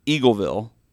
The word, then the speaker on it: Eagleville: Jerry Armstrong is from Missouri, Eaglesville. Did I say that right? – Eagleville. – Eagleville.